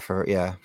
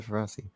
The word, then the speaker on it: Ferreira: Was it Ferreira? – Yeah, Ferreira. Mm-hmm. I don't know why I said Ferrazzi.